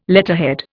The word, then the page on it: letterhead: Indian